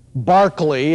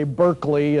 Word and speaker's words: Berkeley: John Locke, Bishop Berkeley, as the English say, Berkeley is what Dr. Van Til used to say. I'll take either pronunciation from you.